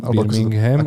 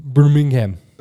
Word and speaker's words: Birmingham: Birmingham? – Birmingham. – Ako sa to vyslovuje? – Birmingham.